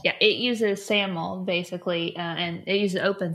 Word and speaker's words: SAML: So OAuth is a protocol, and SAML, is that how you say it right, is another? – Correct, SAML, SAML.